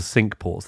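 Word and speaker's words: Cinque: It does mean five, and it's spelt like the French for five, but it is, I swear to God, they pronounce it warden of the sink ports.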